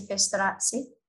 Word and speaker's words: Festorazzi: My husband would say Festorazzi. – Festorazzi. – Yes, that's how he would pronounce it.